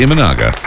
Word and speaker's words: Imanaga: Imanaga? – That's exactly right. – Oh! – Shota Imanaga.